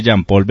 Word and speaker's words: Jean-Paul: Ahí dice Jean-Paul Beltrán, @vidayfinanzas.